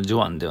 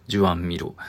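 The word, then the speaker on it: Joan Miró: 今回のジュアンミロ、まあそのジュアンっていうのは、えっ、ー、と、ジョアンではない、なんかそのジョアンが現地の読み方なんですよね。ジュアン・ミロ。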